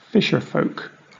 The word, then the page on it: fisherfolk: British English